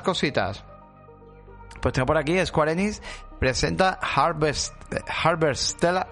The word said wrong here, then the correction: Harvestella: o Harvest eh, coño, ¿cómo se dice? Harvestella, una mezcla de simulador de granja con RPG de acción.